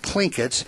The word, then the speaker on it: Tlingits: The Tlingits. – And it's spelled T-L-I-N-G-I-T-S, but the T is pronounced with a K.